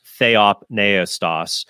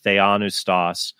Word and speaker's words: theopneustos: because the Catholic magisterium only uses, it says that only scripture is theopneustos. Mm-hmm. White likes to pronounce it and many other Protestants pronounce it theonustos. That's not actually the correct pronunciation.